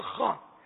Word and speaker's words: خواہ: تہجد خواہ, خواہ مانے وہ پٹھان والا خان نہیں, تہجد خواہ تلفظ ہے کی بنے گا, تہجد خواہ کہنے لگتے ہیں.